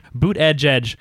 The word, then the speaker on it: Buttigieg: Yeah, look, he has how to pronounce his name. Boot Edge Edge, parentheses, /him.